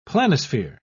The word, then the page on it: planisphere: British English